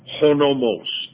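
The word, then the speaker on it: honomos (ὁ νόμος): The English word, the law, with the definite article, comes from the Greek, honomos. That's the way it's pronounced. There's a h before the O.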